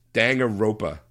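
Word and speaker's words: Danganronpa: Dangaropia 2. Goodbye Despair by NIS close, America. – Close. I think you put an extra name there. It's Dangaropa. – Dangaropa.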